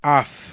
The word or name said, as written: af